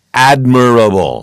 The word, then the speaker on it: admirable: This is the correct pronunciation, admirable.